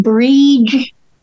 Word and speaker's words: Brid: And she's also called Breed, B-R-I-D, and sometimes that is pronounced Breed.